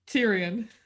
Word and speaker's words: Tyrion: Tyrion, did I say that right? Tyrion? – Tyrion.